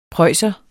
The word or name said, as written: preusser